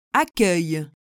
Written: accueil

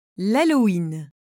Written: halloween

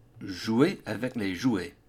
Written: Jouer avec les jouets

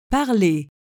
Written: parle

